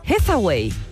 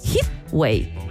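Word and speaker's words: Heatwave: Yo le decía Hathaway. Y se llama Heathway. Hit de calor, como una ola de calor.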